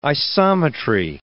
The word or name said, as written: isometry